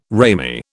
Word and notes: ramie: British English

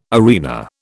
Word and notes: arena: British English

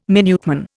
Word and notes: Minuteman: Indian

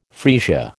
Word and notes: freesia: American English

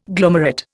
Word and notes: glomerate: Indian

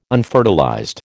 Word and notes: unfertilized: American English

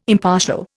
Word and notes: impartial: Indian

